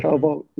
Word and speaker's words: Vogt: I think I've seen Kyle, I just realized I don't know how to pronounce his last name, Kyle Vogt, founder. – Kyle Vogt, yes.